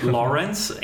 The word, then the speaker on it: Lawrence: Der Lawrence, also muss ich ein bisschen aufpassen heute auch mit der Aussprache, Lawrence, Englisch ausgesprochen,